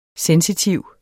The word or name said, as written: sensitiv